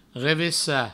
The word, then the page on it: revessar: revessa; Provençal